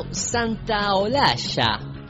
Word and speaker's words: Santaolalla: Y acá hay un puntito también de Mañanas Campestre con Gustavo Santaolalla.